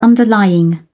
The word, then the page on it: underlying: British English